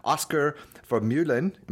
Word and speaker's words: Oscar Vermeulen: Today Jeff and I are speaking to Oscar Vermeulen.